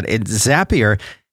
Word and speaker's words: Zapier: Zapier, it's spelled Z-A-P-I-E-R. It rhymes with happier because it will make you.